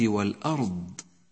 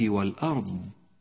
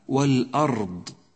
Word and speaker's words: والأرض: وأخيرا بديع السماوات والأرض، بديع السماوات والأرض. حرف الراء ساكن إذا لم تنتبه إلى لفظه تكرر معك في النطق هكذا والأرض، وهذا خطأ شائع نبهتك عليه سابقا، الصواب والأرض، فتنبه له وفقك الله لما يرضيه.